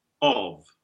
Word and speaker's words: of: So we have a bit of lip rounding. Take your father vowel and put those lips forward. And in this strong pronunciation of the word, of thy rising, which we need because it's very long, we make this rounded vowel, short, rounded O, of.